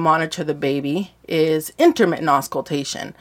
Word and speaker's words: auscultation: It's intermittent auscultation.